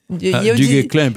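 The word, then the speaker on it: Du Guesclin: Per aquelles qui connaissent pas encore l'adresse del Sir Doc, est à un baulear Bertrand Duguesclin. – Duguesclin. – Comment dire en occita? – Duguesclin, parce que c'est un nom français.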